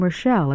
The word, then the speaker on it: Rochelle: My name is Rita, and that's spelled R-I-T-A, and my last name, Rochelle, is spelled R-O-C-H-E-L-L-E.